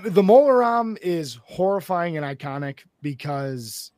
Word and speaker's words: Mola Ram: Yep, this round is Voldemort versus Mola Ram. Like CD-ROM, but apparently it's spelled Ram.